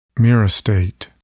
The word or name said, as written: myristate